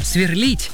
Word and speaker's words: сверлит: Для того, чтобы верно поставить в нем ударение, необходимо подобрать проверочное слово. А это инфинитив, то есть неопределенная его форма «сверлить». Так как в нем акцент ставится на гласную «и», значит, там оно и остается. Поэтому правильно говорить «сверлит», «сверлит» сосед. Кстати, когда последний раз вас вот так вот беспокоил сосед или соседи сверлением?